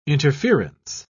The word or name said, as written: interference